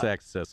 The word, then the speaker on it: Axisa: It's Access, so we've had him on the show before.